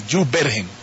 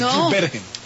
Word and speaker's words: Jubergen: Claro, o sea, no lleva tilde en la E. – Es Jubergen. – Muchas gracias. – No, es Jubergen.